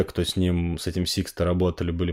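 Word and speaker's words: Sixto: В общем, история в том, что в 70-х годах в Детройте жил такой Сиксто, я правильно говорю? Сиксто, да.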